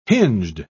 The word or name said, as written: hinged